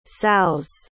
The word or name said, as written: sows